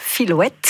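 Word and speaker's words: Filhouette: Ça s'écrit F-I-L-H, et ensuite comme une chouette, Filouette.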